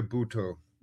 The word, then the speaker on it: Bhutto: And he's coached the luminaries and greats like Eddie Redmayne, Matthew Good, Hugh Bonneville, Emily Clark, the Margaret Thatcher, Mo Mullen, Benazir, is it Bhutto? Did I say that correctly? – Benazir Bhutto.